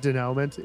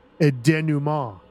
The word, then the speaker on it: denouement: Just so you know, it's not denouement. It's pronounced a denouement.